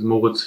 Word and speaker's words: Moritz: Maurice and then Fiebig. I remember last year in the semifinals, in the online semifinals, I went to the camera and said, hi guys, my name is Moritz Fiebig and not Maurice Fiebig.